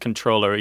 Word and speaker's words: comptroller: I think it's actually pronounced controller, even though there was an MP in there.